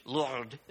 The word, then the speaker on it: Lourdes: Places, you know, like Fatima, Portugal, like Lourdes in France, where, Lourdes, as my daughter says I should pronounce it, or something like that.